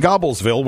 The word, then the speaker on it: Gobblesville: Our crack research department here has looked into it, and not only does it looks like it's spelled Gobblesville, it is pronounced Gobblesville.